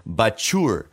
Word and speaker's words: Bachour: i'm ready to move on to sweets and that's perfect because our guest this week is antonio bakur i'm sorry i'm gonna re- i'm gonna do that again our guest this week is antonio bachour because his name is pronounced like a sneeze